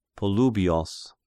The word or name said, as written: Polybios